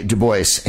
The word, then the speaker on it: Du Bois: W.E.B. Du Bois, he pronounced it that way. How do I know? Because I was corrected in Chicago.